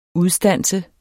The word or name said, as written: udstanse